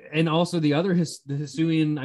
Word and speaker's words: Hisuian: Hisuian, I know it's Hisuian for sure, but the region's Hisua or Hisuia.